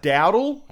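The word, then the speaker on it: Doudle: Mr. Doodle, I mean, I'm sure it's pronounced Dowdle, because it's D-O-U-D-L-E, but I was Doodle.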